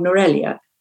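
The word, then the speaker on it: Nuwara Eliya: It's the town I had in mind was called Norelia, which you might know if you've been to Sri Lanka. But it's spelt Nuara Elia.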